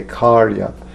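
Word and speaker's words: kārya: The term is karana, karya, kartri, and the karya has a long A. It's in the Devanagari, but the Devanagari on the Veda base is wrong. But in the book, it's a long A, karya, karana, karya, kartri.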